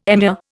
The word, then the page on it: emir: Indian